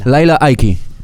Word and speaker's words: Lila Ike: Lindo lo de Laila Ike o Lila Ike. No sé cómo se llama, ¿eh? ¿Cómo se llama? ¿Cómo le dicen? Y los nombres se pronuncian en el origen. Laila. Laila. Ike.